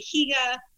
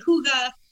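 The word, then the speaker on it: hygge: So if you're Swedish or Norwegian, you say Higa. If you're Danish, you say Huga.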